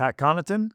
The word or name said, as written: Pat Connaughton